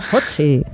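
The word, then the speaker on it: Ötzi: Otzi, creo que se llamaba, un nombre muy, muy noruego. – Utzi, ¿no? – Pues sí, no sé, lo pronuncias tú mejor que yo. – Utzi, vale.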